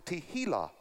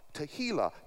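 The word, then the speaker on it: tehillah (תהילה): No, the word there, praise, is the word tehillah. Tehillah. T-E-H-I-L-L-A-H. Tehillah. You want to try to remember how to say it, just think of tequila.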